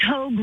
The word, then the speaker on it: Hogue: It's Hogue rhymes with rogue.